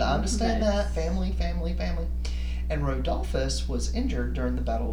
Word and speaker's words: Rodolphus: Rodolphus.